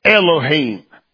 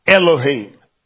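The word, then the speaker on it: Elohim (אלהים): Elohim. If you spell it like it sounds, it's E-L-O-H-E-E-M, Elohim.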